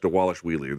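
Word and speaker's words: Dvalishvili: I'm sure I'm fucking his name up, but they say on the broadcast, Dewalish Wheely. There's a V there, but they pronounce it like a W.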